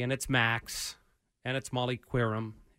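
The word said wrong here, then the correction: Qerim: Is that how you say it? – Kiram. – I apologize.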